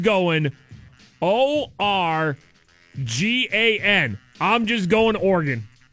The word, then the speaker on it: Oregon: I'm just going Oregon. Oregon. – Like a body organ.